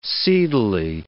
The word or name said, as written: seedily